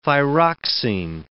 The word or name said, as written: thyroxin